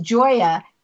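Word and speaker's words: Gioia: It is pronounced Joya, but it is a G-I-O-I-A. And if that's not a different spelling, a G-I-O-I-A, all those vowels.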